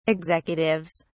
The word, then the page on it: executives: British English